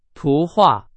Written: 图画

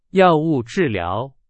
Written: 药物

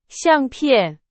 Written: 相片

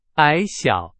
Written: 矮小

